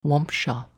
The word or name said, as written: Wompshaw